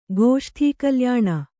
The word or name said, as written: ಗೋಷ್ಠೀಕಲ್ಯಾಣ